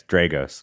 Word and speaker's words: Dragos: Yeah, it's Dragos. Like, it's the long A.